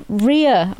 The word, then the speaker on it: Rhea: Her name is spelled Rhea. – Oh, Rhea. – Right. Is it R-H-E-A? – Rhea. I would pronounce that as. – Rhea. – Right. – Oh, like diarrhea.